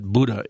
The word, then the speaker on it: Buddha: B-U-D-D-A. Okay. Although some people spell it with just a single D. I've also heard it pronounced Buddha, okay. – But I've been assured by someone who speaks the language that Buddha is the correct pronunciation.